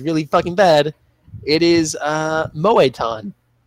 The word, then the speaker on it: Moetan: I have a feeling there's an accent It's Moe-tan. How you think you would spell it, it's like just all one word. M-O-E-T-A-N.